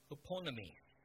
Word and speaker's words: hupomone: The Greek word for patience is huponomi. So it's spelled H-U-P-O-M-O-N-E.